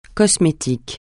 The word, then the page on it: cosmétique: francais